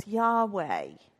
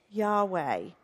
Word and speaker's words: Yahweh: There are no vowels in Hebrew, and so in English we pronounce this Yahweh. Yahweh.